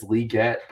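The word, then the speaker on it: Legette: Again, I'll say both pronunciations because he did say that it is Leggett, but everyone calls him Leggett.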